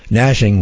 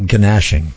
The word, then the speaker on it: gnashing: Gnashing with a G. I always call that gnashing, but no, the G is silent.